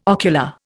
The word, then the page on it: ocular: Indian